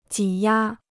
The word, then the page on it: 挤压: jǐ yā